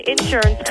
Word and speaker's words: insurance: I work at an insurance company. – Boy, I like how you say insurance as opposed to outsurance. You put that emphasis on the in.